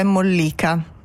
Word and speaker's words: mollica: Sempre in cucina, problemi con l'accento, si dice mollica o mollica, che questa cosa soprattutto al nord è un po' diffusa. Allora, mm-hmm. Deriva dal latino e vuol dire appunto molle, sì. – E l'accento corretto è mollica.